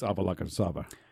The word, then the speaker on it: Avalokitesvara: The Dalai Lama is also considered to be a successor in a line of Tolkas, who are believed to be incarnations of, I'm going to butcher this name, Avalokitesvara. – It's Avalokitesvara.